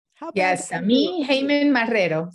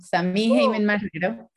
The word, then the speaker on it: Samí Haiman-Marrero: So today's guest, and you're going to have to tell me if I'm saying it correctly, honestly, Samí. It's Samí Haiman-Marrero. – Samí Haiman-Marrero. Samí Haiman-Marrero. – Samí Haiman-Marrero. Okay, yes.